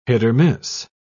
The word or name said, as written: hit-or-miss